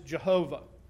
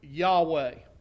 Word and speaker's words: Yahweh: Secondly, I point out to you today, this primary name for God is Jehovah. That's the way we pronounce it in English. It's translated as Lord in all capital letters in our Old Testament. The Hebrews pronounce it, if they pronounce it, Yahweh.